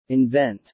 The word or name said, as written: invent